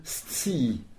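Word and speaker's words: scii: Le verbe sci, qui s'écrit S-C-I-I. S-C-2-I. Alors, il faut entendre le premier S pour le S, et juste après T-S, T-S pour le C. Donc, sti.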